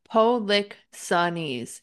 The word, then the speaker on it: Polixenes: Polixenes. Yeah.